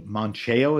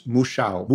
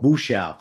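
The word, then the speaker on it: Mouchão: Is that how you pronounce it? – No, it's Muxiao. – Muxiao. – Muxiao. – Mm-hmm. The wire is in front of the U. Muxiao. M-O-U-C-H-A. – A with a tilde.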